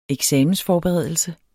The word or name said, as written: eksamensforberedelse